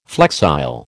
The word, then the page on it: flexile: American English